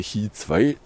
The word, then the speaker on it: Qi 2: dann kann das Qi 2. Oder wie es korrekt ausgesprochen wird. Qi 2.